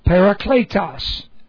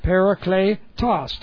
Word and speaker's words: parakletos: That's P-A-R-A-K-L-E-O. Parakaleo. Parakaleo. And it comes from the Greek word parakletos. Parakletos. Well, just P-A-R-A-K-L, long E, parakletos, T-O-S, parakletos, which comes from parakaleo.